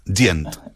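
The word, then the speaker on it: djent: ¿Y se dice así o Dient? – Dient. – Se, dice Gent.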